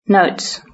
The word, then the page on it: notes: British English